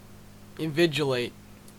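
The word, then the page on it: invigilate: Canadian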